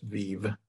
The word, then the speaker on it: Veve: Or you can go to Veeve, V-E-V-E. And let me go on right now to see what's on there. – You pronounced it Veeve, but it's V-E-V-E. – Yeah, you know what? It's my Spanish. It's just Veeve, V-E-V-E.